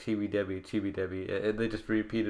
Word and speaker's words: Chibi Devi: They pronounce it as Debi in the anime, because the V sound doesn't really exist in Japanese. Chibi Debi.